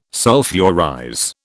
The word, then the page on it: sulphurize: British English